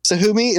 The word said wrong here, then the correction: Kasumi: is that how you pronounce it? Or is it Tsumi? – I believe it's Kasumi.